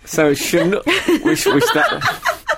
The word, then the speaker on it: Chinook: So, Chinook is what it should be. – Karen Wanamill. Chinook. So, it's always possible that the original pronunciation is with a hard ch sound, of course.